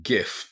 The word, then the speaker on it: gift: gift, gift. 贈り物です。これも、ギフトって言わないよね。 gift, ふと、最後は、ふと。